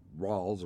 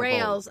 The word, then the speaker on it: rales: And we call those particular sounds Rawls, R-A-L-E-S. – Yes. – And so that's pronounced Rawls or rail? No, they don't rails. – Call it, no, you called it rails? – We did. – I call it Rawls.